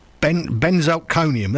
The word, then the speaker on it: Benzalkonium: Benzochlorine chloride or something like that. Anyway, it's not quite like that. I can't pronounce it. Benzalkonium. There we go.